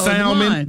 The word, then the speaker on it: salmon: That's salmon. That's salmon. – Salmon has that, well, that L is supposed to be silent, ain't it? – It's supposed to be.